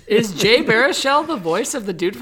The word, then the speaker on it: Baruchel: Jay Baruchel finds himself, I don't know how to say the name, is that right? – Yep. – Baruchel finds himself in a dangerous position of befriending what he once thought his enemy, Ted Mosby's wife.